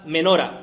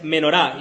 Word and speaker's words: menorá: En hebreo, la palabra que está aquí usada por candelero es la palabra menora, o más bien dicho, la pronunciación más correcta es menorá.